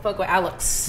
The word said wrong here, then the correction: Alox: Yeah, Alox. Yeah, Alox. So it's Alox.